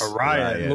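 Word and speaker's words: Arraez: Arias? – Arias. – Whatever Arias, it is. Yeah. I mean, Jesus Christ. But Luis, how do you say it Arias. – Again? – Arias.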